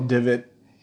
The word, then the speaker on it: dybbuk: That's literally all you have to D-Y-B-B-U-K. – Okay, so knew how to spell it. Yes. I know that the pronunciation does not sound... like the spelling.